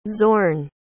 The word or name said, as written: xorn